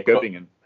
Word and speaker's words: Göppingen: So you're originally from Göppingen. Am I saying that right? – Yeah, Göppingen.